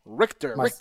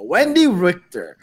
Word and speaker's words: Richter: but for the first person, we're gonna talk about and always I'm probably always gonna butcher these names, so always correct me. Wendy Richter. – Richter. – There you go. Wendy Richter.